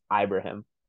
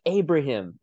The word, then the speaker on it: Ibrahim: Last name is pronounced Ibrahim. There was an announcer on the Wisconsin Minnesota game, which is by all intents and purposes a big football game. You should have a good crew doing it. There's this the play-by-play guy pronounced Ibrahim's last name incorrectly 3 different times on one drive. At one point he's like Abraham, and then he goes and Ibrahim. I'm like, you just said Ibrahim 3 sentences ago, and now you're calling him Abraham and Ibrahim.